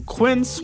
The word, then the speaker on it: Quince: Today Explained's support today comes from Quince, which rhymes with since, but is spelt with a Q-U.